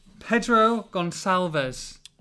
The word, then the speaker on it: Pedro Gonçalves: Pedro Gonçalves. – Well pronounced.